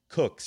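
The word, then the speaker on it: Coke: Sir, now this is pronounced Cook, Sir Edward Cook.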